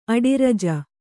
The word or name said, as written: ಅಡಿರಜ